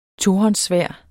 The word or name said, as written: tohåndssværd